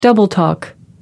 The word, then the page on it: double talk: American English